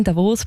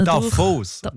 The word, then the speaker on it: Davos: Und da wären wir aufgewachsen in Davos, Bündner Davos, durch. Nicht Davos.